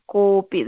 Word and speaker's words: कोऽपि: That S kind, no, in the middle, you have to say, you just pronounce kopi, that's all.